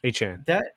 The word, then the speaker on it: Achane: Achane.